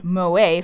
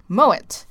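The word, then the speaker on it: Moët: I just found out something I called Moe for the longest time is actually pronounced Moet.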